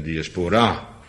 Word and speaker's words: diaspora: En ik geloof dat het fout is, want dat het moet zijn diaspora. Nou ja, geen Engelsman die het zo zal uitspreken, maar het is ook geen Engels.